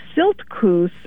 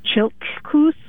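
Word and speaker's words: Siltcoos: Silt Coos originally is Chilt Coos. – Chilt Coos. – In the native pronunciation.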